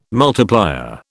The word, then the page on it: multiplier: British English